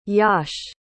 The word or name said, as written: Yash